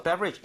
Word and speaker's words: beverage: Okay, so beverage. 이것도 발음을 한번 해볼게요. 앞에 BE 쪽에 강세를 주고 따라해 주세요.